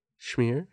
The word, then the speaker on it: schmear: Schmear? What? Am I saying it wrong? – No, you're saying it perfectly.